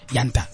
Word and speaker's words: Yanta: We're still chilling with Sandy, Mazibuku, and my boy Sibusiso Yanta.